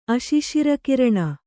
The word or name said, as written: ಅಶಿಶಿರಕಿರಣ